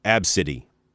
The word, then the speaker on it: Abcde: Her name is A-B-C-D-E. That's how you spell it. It's pronounced, though, Absidy.